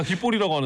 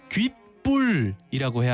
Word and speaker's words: 귓불: 우리가 다 귓볼이라고 하는데? – 아니에요. 아닙니다. 자 귓바퀴 아래쪽에 붙어 있는 이 살 귓볼이 아니라 귓불이라고 해야 합니다. – 아, 불 불이에요. 불 발음할 때 귓불이겠죠. 귓불.